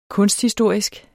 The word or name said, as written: kunsthistorisk